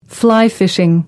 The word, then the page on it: fly fishing: American English